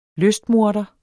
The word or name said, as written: lystmorder